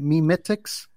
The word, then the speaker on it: memetics: Am I saying that right? Memetics? – Memetics. – Memetics. – You're doing a good job on that.